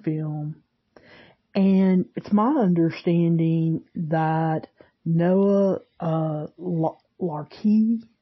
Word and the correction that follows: LaRoche: I believe is how you say his name, LaRoche, sorry, I actually wrote down how to, to say it, LaRoche.